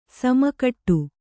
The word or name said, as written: ಸಮಕೂಟ